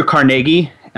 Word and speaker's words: Carnegie: Barnum, Andrew Carnegie. I'm going to go with that pronunciation, by the way. – Okay. – Carnegie. it's more Scottish.